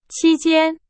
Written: 期间

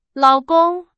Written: 老公

